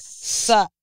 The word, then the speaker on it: SZA: It's SZA.